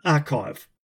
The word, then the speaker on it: arXiv: First up, from a paper dated 27th of February 2019, published online in Archive.